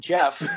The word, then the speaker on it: Jeff: Like J-I-F-F, Jiff, with a little bit of a which I love. I think it's way better than Jeff, which is how I pronounce my name.